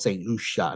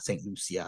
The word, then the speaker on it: Saint Lucia: I've been pronouncing their name wrong because they're named after the Caribbean nation, so they're called Saint Lucia, not Saint Lucia, as I was calling them previously.